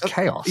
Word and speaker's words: XAOC: Is it Chaos? XAOC. – Yeah, that's right. That's right. Yeah, I've got their. – I think it's pronounced Chaos. – Yeah, I think you're right.